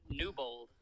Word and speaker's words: Newbold: How do you say your last name? – Newbold. Like, why are you saying it Newbold? – Okay, listen.